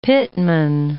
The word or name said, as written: pitmen